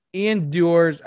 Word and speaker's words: Ian Dior: I mean, I gotta say, like, Ian Dior's, I'm not gonna pronounce his name right, forgive me. I'm terrible. – No, you got it right there. – Oh, good. Yay. Ian Dior.